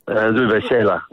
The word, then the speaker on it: Uwe Seeler: Uwe Seeler, the German. Is it Seeler? I was going to say Seeler. – Yeah, Uwe Seeler, yeah.